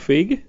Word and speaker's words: Feig: And, of course, like Paul Feig, did I pronounce that correctly? – Yep.